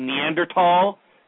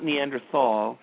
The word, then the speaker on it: Neanderthal: Neanderthal. That's the way you're supposed to pronounce it. It's not Neanderthal. It's Neanderthal.